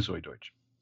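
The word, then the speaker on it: Zoey Deutch: Primarily Zoe Deutsch. – Yeah, it's going to be a Deutsch pod. I looked it up. That is a correct pronunciation.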